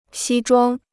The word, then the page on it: 西装: xī zhuāng